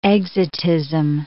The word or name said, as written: exotism